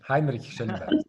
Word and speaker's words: Heinrich Schellenberg: Este, queremos agradecerle muchísimo al embajador de la República de Suiza, eh, Heinrich Schellenberg, ¿lo dije bien? – Sí, Heinrich Schellenberg.